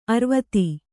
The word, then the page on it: ಅರ್ವತಿ: Kannada